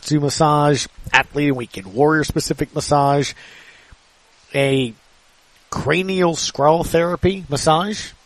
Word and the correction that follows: craniosacral: Did I? Sacral Okay, I mispronounced that. Thank you, Dr. Josh. You literally just walked out enough to tell me craniosacral.